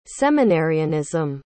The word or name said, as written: seminarianism